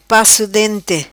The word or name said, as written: paco dente